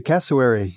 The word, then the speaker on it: cassowary: cassowary. Did I say that right? – That's right. The cassowary.